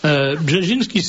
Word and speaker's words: Brzezinski: C'est le bouquin de Zbigniew Brzezinski. Ah oui, ça s'écrit comme ça, ne se prononce pas. Brzezinski, c'est B-R-Z-E. – Il y a toujours des S qui... Alors, B-R-Z-E...